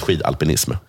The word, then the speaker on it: skidalpinism: Alltså skidalpinism måste det väl uttalas? – Det uttalas skidalpinism.